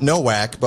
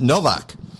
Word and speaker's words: Nowak: My name is spelled Nowak, but it's Polish. So it's not Nowak, but my parents pronounced it Novak.